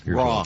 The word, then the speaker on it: Roy: And Patrick Raul, we pronounce Raul, it's spelled Roy, R-O-Y. Patrick Raul, how do you pronounce Raul? Raul? So I'd rather pronounce that. Patrick Raul, the Hockey Hall of Famer, 61. – Raw. Patrick Raw.